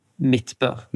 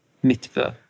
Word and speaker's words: Midtbø: Yeah, M-I-D-T-B-O. – How do you say your last name? – Midtbø. – Midtbø, okay. – Midtbø, yeah.